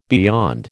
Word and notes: beyond: American English

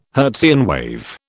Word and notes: Hertzian wave: British English

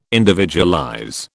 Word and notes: individualize: British English

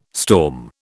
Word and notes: storm: British English

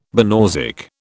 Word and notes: banausic: British English

